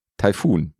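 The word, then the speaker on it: Taifun: Aber dass die Naturkatastrophe nicht Typhoon heißt, sondern Typhoon. – Mhm. – Und die Betonung macht eben aus der Naturkatastrophe schnell mal mein Pokerkumpel, mit dem ich öfter mal unterwegs bin.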